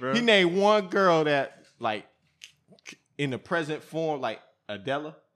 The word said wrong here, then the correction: Adele: That's her name? – Adele.